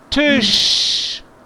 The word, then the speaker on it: Tisch: Como shop, de... shop. Ah, okay. Esa, no. Esa letra del fonético alfabet es sh. Como shop, shut, Schokolade, sh. Tisch, Tisch, Tisch, ja.